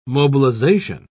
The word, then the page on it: mobilisation: British English